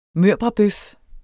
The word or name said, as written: mørbradbøf